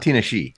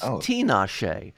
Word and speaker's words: Tinashe: It's not Tinashe. – No, it's not Tinashe. It's not Tinashe either. It's Tinashe, as in Tina. Tinashe.